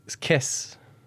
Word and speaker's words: caisse: The second one is kiss. – Kiss. Can you spell it? – Kiss. C-A-I-S-S-E? – Double S-E.